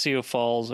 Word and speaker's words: Sioux Falls: I think the X is silent. I think it's just Sioux Falls or so.